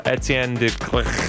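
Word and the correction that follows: Étienne de Crécy: coming up, right? – Oh, yeah. Forgot about that one. December 13th, Etienne de Crécy. – We'll go with that. – I butchered it.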